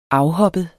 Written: afhoppet

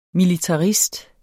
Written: militarist

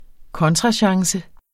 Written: kontrachance

